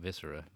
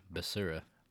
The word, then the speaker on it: Basura: No, not Viscera, Basura. – Oh. – B-A-S-U-R-A.